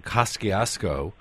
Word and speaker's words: Kosciuszko: With some of the other places that confuse people is the Kosciuszko Bridge and the street in Brooklyn, or as New Yorkers pronounce it, often Kosciuszko, named after a Polish general who fought in the American Revolution? – Well, not so much.